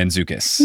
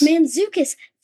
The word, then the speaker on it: Mantzoukas: Mantzoukas. – Mantzoukas. That's how you say his name.